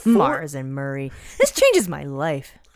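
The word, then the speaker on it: Murrie: M&M is Mars and Murray. It's M-U-R-R-I-E, which I assume is pronounced Murray. – Okay, that makes sense. But I always thought it was M-E-R-R-I-E.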